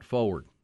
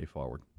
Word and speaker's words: forward: And somebody said, it's forward, not forward. Yeah, I did tend to not pronounce the R when I say forward. I kind of spell it F-A-W.